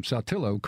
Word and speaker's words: Saltillo: But the announcers pronounced it Saltillo or something like that. Okay. And someone from Saltillo called and said, it's Saltillo. So he had to come back, like me, and correct the pronunciation of Saltillo. – Yeah, some people want to say Saltillo. – Saltillo. – Yeah, which is not the way that they say it up there. – Yeah, it's not necessarily pronounced the way it's spelled.